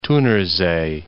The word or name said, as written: Thunersee